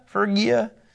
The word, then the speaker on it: Phrygia: Phrygia.